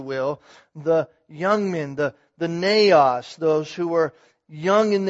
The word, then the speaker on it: neos: the Greek word neos, N-E-O-S, if you want to spell it.